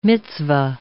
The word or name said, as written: mitzvah